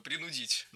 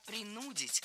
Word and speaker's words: принудить: Не принудить, а принудить. Ударение ставим на второй слог, на корень слова. И во всех формах его сохраняем. Принудить, принудил, принудишь.